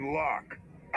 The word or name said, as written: Lok